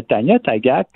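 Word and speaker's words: Tanya Tagaq: Euh, ta critique de Croc fendu de Tania Tagac, est-ce que c'est comme ça qu'on le prononce? – Exactement. Euh, Tania Tagac, euh, pour t'expliquer, c'est une, chanteuse.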